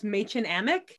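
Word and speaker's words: Mädchen Amick: Played by, her name is so hard to pronounce. I think it's pronounced Machen Amick.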